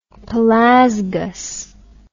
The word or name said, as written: Pelasgus